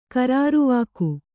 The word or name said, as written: ಖರಾರುವಾಕ್ಕು